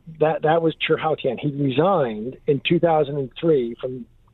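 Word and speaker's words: Chi Haotian: There was a guy named, in Chinese it's pronounced Chih Haotian, C-H-I, and then the next word is H-A-O-T-I-A-N.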